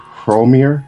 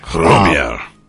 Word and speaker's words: Hromir: The Song of Romir, or Hromir. It's got that H with the R right in front of it. – Mm. Hromir.